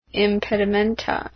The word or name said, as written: impedimenta